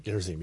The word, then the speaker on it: Gerizim: Moses charged the people that the same day, saying, these shall stand upon Mount, how you pronounce it? Gerizim? – Gerizim, yes.